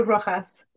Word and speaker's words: Rojas: Maru, I've been pronouncing your name not accurately for all this time. Would you be willing to say it accurately for me? – Yeah, it's Maru Rojas.